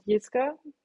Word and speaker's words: Jiska: So in Germany, if something starts with a J, we pronounce it Jiska.